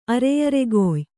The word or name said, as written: ಅರೆಯರೆಗೊಯ್